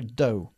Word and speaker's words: dough: Que se escribe como dough, pero se pronuncia dough.